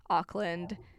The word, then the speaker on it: Auckland: Yes, very connected, Madison and Auckland. Am I saying that right? – Yeah, Auckland. – Auckland. Okay. – Auckland. – The American way.